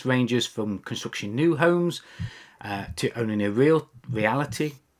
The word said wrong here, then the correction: realty: Yeah, I think that's right. Reality. – Realty. – Executive franchise. Realty. Ah, right. Okay. Slight different UK pronunciation then.